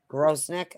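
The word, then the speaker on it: Grosenick: It's Grosnick. Is that how you say it? Grosnick, okay. – Yep.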